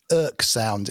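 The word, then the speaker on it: oeksound: There is another on the market by, I think they're Oek Sound is how you pronounce it,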